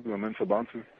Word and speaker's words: Mensah-Bonsu: Uh, Bonsu, Mensah-Bonsu.